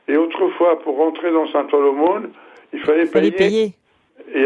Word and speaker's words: Saint-Ouen-l'Aumône: Saint-Ouen-l'Aumône.